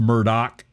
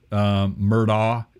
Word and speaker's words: Murdaugh: Murdaugh, and that's spelled, it's pronounced Murdaugh, which is weird. Murdaugh, it's A-U-G-H, M-U-R-D-A-U-G-H.